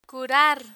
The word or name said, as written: curar